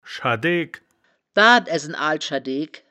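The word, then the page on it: schadeegk: Wittlich dialect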